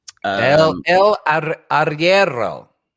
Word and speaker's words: Arriero: El Arriero.